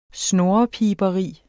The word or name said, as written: snurrepiberi